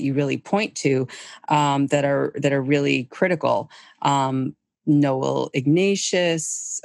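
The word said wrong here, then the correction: Ignatiev: you talk about him. Why is he important? – Well, Noel Ignatieff. – Ignatieff.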